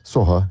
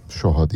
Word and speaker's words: Soha: Soha ya da Şoha diye de telaffuz ediliyor ismi.